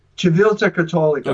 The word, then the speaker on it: Civiltà Cattolica: This was Civiltà Cattolica. Is that how you pronounce that? – Civiltà Cattolica.